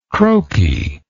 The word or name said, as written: croakiness